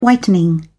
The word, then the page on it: whitening: British English